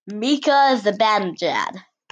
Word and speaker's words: Mika Zibanejad: Mika Zibanejad.